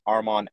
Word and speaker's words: Armand: My username is at Armand Alex. And the easiest way to remember my name is my arm is on, and that's exactly how you spell it, arm on Alex.